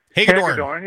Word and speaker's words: Hagedorn: and then Hilgendorf, or whatever his name is, Hilgeborn, or Hagedorn. – I can't get the name wrong. – Hagedorn.